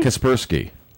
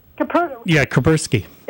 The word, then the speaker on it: Kaspersky: Somebody recommended Kaepernicki. Am I pronouncing for an internet security? Am I pronouncing it correctly? – Kaspersky. – Kaspersky.